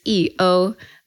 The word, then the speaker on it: YiOu: And my name, the characters YiOu, the pinyin spelling for the first character E is YI, and the pinyin spelling for the second character O is OU.